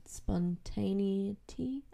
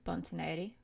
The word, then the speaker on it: spontaneity: Things like incorporating, what's the word, spontaneity? I can't say it properly. – Spontaneity. – There we go.